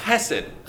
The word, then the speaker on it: chesed: The word in the Bible is chesed. You have to say it like that, like a Hebrew.